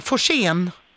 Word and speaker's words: Forsén: Gun-Britt, hur uttalar man ditt efternamn? Forsén? – Ja.